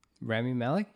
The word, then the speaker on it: Rami Malek: Rami Malek. – Oh, boy. – Is that his name? Did I say that correctly? – Rami Malek? – There you go.